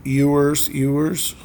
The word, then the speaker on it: Ewers: Quinn Ewers. – Yeah, that's right. – Out of Texas. – That was one pronunciation I was able to verify.